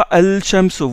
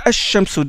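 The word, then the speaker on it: ash-shamso (الشمس): Seulement, le lam, vous ne le prononcez pas, vous ne dites pas al-shamso, vous dites ash-shamso direct. Donc le lam, il entre dans le sha, dans la lettre shin.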